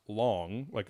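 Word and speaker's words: long (龙): They're called in Chinese or in Mandarin, it's pronounced long, like long, like Shenlong from Dragon Ball and Dragon Ball Z and whatever.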